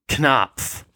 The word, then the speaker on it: Knopf: It's German, so the original pronunciation would be with a short, hard K. – Knopf.